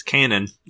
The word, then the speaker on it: Canaan: It's Canaan, like the biblical figure.